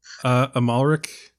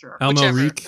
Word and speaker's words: Amalric: Matthew Almerich, or however you say his name. I was like, yes. Oh my God. – Yes. Isn't it just Matthew Amalric? – Sure. – Amalric.